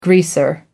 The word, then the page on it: greaser: American English